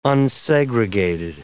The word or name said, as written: unsegregated